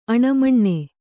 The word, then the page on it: ಆನೆಮೀನು: ānemīnu